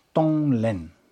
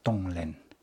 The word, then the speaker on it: Tonglen: Tonglen, there are two syllables. Tong, to give. Len, to take. Tonglen.